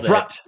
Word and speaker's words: Brok: Another game another demo I played that I thought was interesting is a game called Brok the Investigator. I pronounce it that way because he's a alligator.